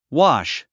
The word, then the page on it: wash: wɑ’ʃ; ワァッシュ